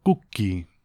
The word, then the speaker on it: Cucchi: El nombre, claro, para que se pronuncie así en italiano, se tiene que escribir Cucchi. Y es el artista que nos acercaba a su disco Play Dances, que se publicaba a principios de este año 2023.